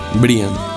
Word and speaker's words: Brian: Veo que usted dice Brian, se escribe Brian. – Brian. – Correcto. Esto es porque de pronto la gente va a escribir Brian en el correo y no lo van a encontrar por ningún lado, ¿no?